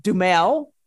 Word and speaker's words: Duhamel: Now, again, her backstory, she used to be married to Josh Duhamel, is how you pronounce his last name.